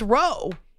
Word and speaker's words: row: But row, it's pronounced row, is a word that may be used as a noun or a verb. Duh. There are rows that refer to brawl, or you can also row. Not row your boat, but row as in fight.